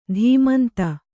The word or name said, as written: ತಮಂಧ